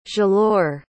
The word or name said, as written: zhalore